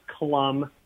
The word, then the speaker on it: Clum: No offense, it is pronounced Klum. – I knew it. – Klum. Damn it. – C-L-U-M.